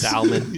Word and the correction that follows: Solomon: Yeah. – I think it's Solomon, but yeah.